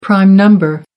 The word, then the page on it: prime number: American English